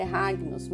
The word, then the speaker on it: Hagnos: Eu acho que a pronúncia é Ragnos, mas ela é escrita com H-A-G-N-O-S. Ok? Então é um,